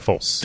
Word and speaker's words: Folse: After graduating from the Chef John Folse, am I saying it correctly? Fulsey Culinary School. – no, uh, Chef John Folse.